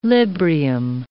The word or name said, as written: Librium